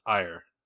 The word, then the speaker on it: Eire: Michael, it's not pronounced eerie. It's pronounced ire. – What? – E-I-R-E. Ire.